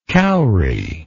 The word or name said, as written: cowry